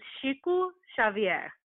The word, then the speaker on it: Chico Xavier: His name is, so in Portuguese, his name is Chico Xavier. – Beautiful. And the book that it's based on is called, it's spelled N-O-S-S-O-L-A-R, and it translates to our home.